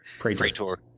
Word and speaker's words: Praetor: Praetor.